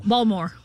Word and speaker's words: Baltimore: Balmer. – Yeah, it's Balmer. I mean, Balmer. A lot of times it's B-A-L-M-E-R. Mm-hmm. Or the T's in Baltimore, where I'm from.